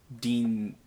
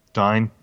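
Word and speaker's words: Dyne: Now, it kind of makes me wonder, based on, like, Dean? How do you say that? Dine? – Dine, yeah.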